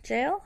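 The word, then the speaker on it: gaol: It's actually called the Old Mount Gambier Jail? How do you say that word? – Well, it's pronounced jail.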